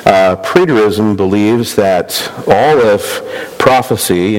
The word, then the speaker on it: preterism: And this view is called preterism. Let me spell that for you. P-R-E-T-E-R-I-S-M.